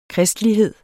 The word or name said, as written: kristelighed